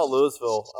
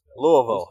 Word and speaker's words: Louisville: I mean, I saw Louisville beat Florida State. Poor Jack. – This motherfucker said Louisville. – Louisville. How about that? Louisville. – What is happening? What is happening? Right. Fewer syllables, the better.